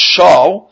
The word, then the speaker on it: shav: So therefore, the word shav, to lie, was really pronounced shaw, and shoah, which means destroyed, are really very similar in sound.